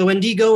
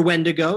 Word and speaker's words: Wendigo: So with the Wendigo or Wendigo, it depends on where where you're from, how you say it.